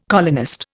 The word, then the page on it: colonist: Indian